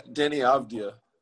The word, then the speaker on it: Deni Avdija: Yeah, Deni Avdija.